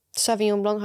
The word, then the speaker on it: Sauvignon Blanc: Sauvignon Blanc, how you pronounce it.